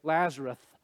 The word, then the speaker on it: Lazarus: That cracks me up that I just said Lazarus, because sometimes the old timers would say Lazarus. It's really Lazarus. Let's say that together just so we're all phonetically correct. Lazarus. There is no Lazarus. Okay, it's Lazarus. Us.